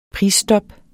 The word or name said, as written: prisstop